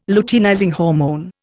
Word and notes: luteinizing hormone: Indian

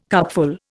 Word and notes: cupful: Indian

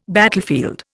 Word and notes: battlefield: Indian

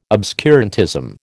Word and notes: obscurantism: American English

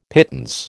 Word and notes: pittance: American English

